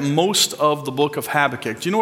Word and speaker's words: Habakkuk: Do you say Habakkuk or Habakkuk? Habakkuk, okay. It just depends what syllable you put the emphasis on, I guess.